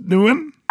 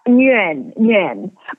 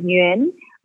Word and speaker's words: Nguyen: Nguyen? – Nguyen. But in Vietnamese, it's like Nguyen. But I think in America, they pronounce it as like Nguyen.